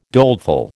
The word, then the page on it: doleful: American English